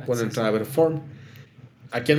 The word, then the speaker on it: Formed: Y el año pasado iniciamos Formed en español. Es que Formed se pronuncia Form en inglés,